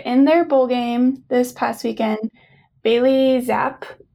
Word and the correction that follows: Zappe: I think is how you say his name, Western Kentucky's quarterback. Zappy. Yeah, Zappy, broke Joe Burrow's single season record for touchdowns and passing yards.